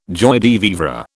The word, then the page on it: joie de vivre: British English